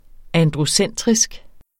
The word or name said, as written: androcentrisk